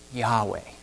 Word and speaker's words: Yahweh: The Hebrew for that is only four consonants, YHWH. And the ancient rabbis believe that it's pronounced Yahweh.